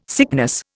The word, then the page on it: sickness: Indian